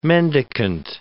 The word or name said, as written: mendicant